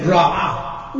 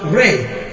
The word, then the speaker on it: Re: So when they said Ra as disagreeable or evil in the Hebrew of the Bible, they were saying Ra'ah within. And people said, see, that's the Egyptian deity. Totally wrong. Egyptian deity is not even pronounced Ra in ancient Egypt. It's pronounced Re, from which they stole the word R-A-Y for the rays of the sun.